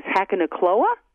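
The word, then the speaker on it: Hakonechloa: But the botanical name for the Japanese forest grass is Hakanokloa.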